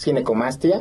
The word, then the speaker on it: ginecomastia: Pues muchas gracias, y sí, definitivamente creo que es una, para empezar la palabra, le decía, ¿cómo se pronuncia? Ginecomastia. – Sí, exacto, es ginecomastia.